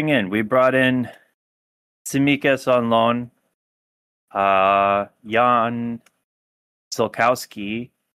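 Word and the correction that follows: Ziółkowski: Even I pronounced his name wrong. It's Zhukovsky.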